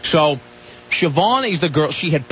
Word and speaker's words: Siobhan: There was this girl named Siobhan, and I'm assuming that's how you pronounce that. – Yep. – Siobhan,